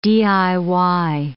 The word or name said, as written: DIY